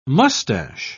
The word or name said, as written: mustache